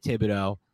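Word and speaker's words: Thibodeaux: And hopefully they can, like, the Giants can finagle their way into a spot where they can get Thibodeau. All right, is that how you pronounce his name? – Yeah, Kayvon Thibodeau, the Oregon pass rusher.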